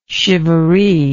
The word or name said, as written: chivaree